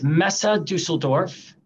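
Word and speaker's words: Messe Düsseldorf: Sure, it's Messe Dusseldorf.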